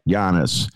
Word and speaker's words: Giannis: Giannis.